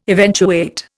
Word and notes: eventuate: Indian

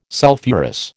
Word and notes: sulphurous: American English

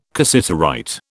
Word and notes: cassiterite: British English

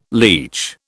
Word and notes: leach: British English

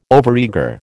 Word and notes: overeager: American English

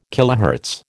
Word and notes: kHz: American English

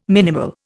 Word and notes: minimal: Indian

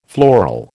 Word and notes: floral: American English

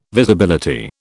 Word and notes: Visibility: British English

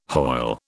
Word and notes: Hoyle: British English

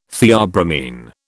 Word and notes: theobromine: British English

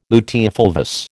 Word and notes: luteofulvous: American English

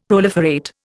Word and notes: proliferate: Indian

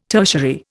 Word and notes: tertiary: Indian

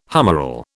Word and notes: humeral: British English

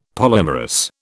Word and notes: polymerous: British English

